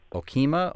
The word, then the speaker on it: Okemah: Okemah, Oklahoma. Is that how you say the name of that town? – Yeah, very good. Not many people pronounce that right on the tongue.